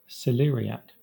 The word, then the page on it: celeriac: Southern England